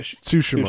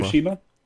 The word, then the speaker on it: Tsushima: Ghost of, how do you say it? – Tsushima. – Tsushima? – Yeah. – Tsushima. It says, Ghost of Tsushima.